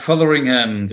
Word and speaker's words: Fotheringham: der auf den Namen Fotheringham hört. Ist das richtig ausgesprochen? – Mark Fotheringham, richtig.